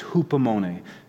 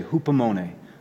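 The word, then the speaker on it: hupomone (ὑπομονή): Perseverance in Greek is hupomone. Can I have you guys say that with me? Because it's a fun word to say, hupomone, hupomone. Just a reminder, it rhymes with poopomone.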